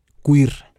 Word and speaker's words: Cuir: Este nuevo proyecto se llama Queer, Historias Disidentes. Eso se escribe como si lo pronunciaras en español, C-U-I-R.